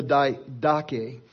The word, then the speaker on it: Didache: but it's called the Didache.